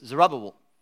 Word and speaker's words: Zerubbabel: So it's Zerubbabel, right? Got it, nailed it. Let's do it together, Zerubbabel.